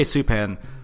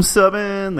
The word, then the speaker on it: Subban: Why are you saying it like that? Subban. – Subban? – Yeah. – Is that really how you say it? – Yeah. – I've just been saying Subban. – Subban is totally wrong.